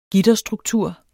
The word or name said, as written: gitterstruktur